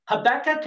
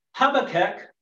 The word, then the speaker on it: Habakkuk: In English, Habakkuk can be pronounced as Habakkuk or Habakkuk. So I want to know how it's pronounced in Hebrew, so I asked my Jewish tour guide. He said it is Habakkuk. So I think some say it sounds like have a cook.